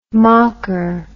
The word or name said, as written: mocker